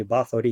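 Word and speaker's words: Bathory: Его зовут Золтан Баттери, ну или Баттери, если правильно, да, там произношение там выстраивать.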